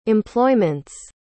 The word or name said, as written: employment's